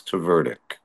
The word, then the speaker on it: Tverdyk: We sent all our vowels to Italy. So we don't have so many. I have one in a three-syllable name, so it's Tverdyk.